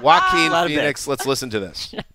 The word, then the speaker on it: Joaquin: and Jacqueline. Is that how you pronounce it? – Joaquin Phoenix. – Joaquin.